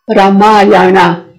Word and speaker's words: رامایانا: راما که اسم راماست یعنی لذت، آیانا یعنی راه، مسیر، راما راه راما، رامایانا، رامایان، تلفظ صحیحش به این صورته در سانسکریت.